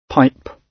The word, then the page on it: pipe: British English